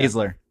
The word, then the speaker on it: Baszler: Yo, is Baszler? Is that how you say it? Baszler? – Yeah, Baszler. – Yeah. Baszler.